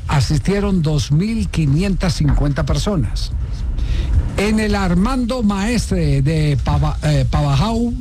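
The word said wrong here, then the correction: Pavajeau: Pabayó. – Es Pabayó. – Sí, se pronuncia Pabayó.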